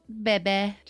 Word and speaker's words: Babe: And the single is called Bebe. But it's spelled like babe, B A B E.